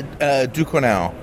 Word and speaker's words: Ducournau: This is Julia Ducournau. Yeah. I probably butchered that. – That sounds about right.